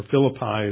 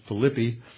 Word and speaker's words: Philippi: which is the main harbor serving Philippi, or Philippi as it's pronounced in English, but the Greeks pronounce it Philippi.